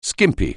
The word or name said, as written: skimpy